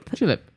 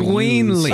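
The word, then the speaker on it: Gueinlie: Gweenly. Huh? Gweenly? – Oh my god, you pronounce it correctly? Nobody, I just gave up.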